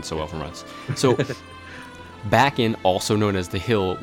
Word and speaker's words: Bakken: So the first amusement park is believed to be in Bakken in... Did I say that wrong? Bakken? – Yeah, Bakken in Denmark.